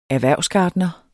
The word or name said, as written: erhvervsgartner